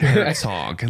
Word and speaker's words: Herzog: Like Herzog, like hog. Oh, Germans. Oh, you know, the Herzog.